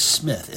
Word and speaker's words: Smith: Now, my name is Smith, S-M-I-T-H, in case you can't spell it.